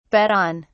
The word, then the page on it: bet on: British English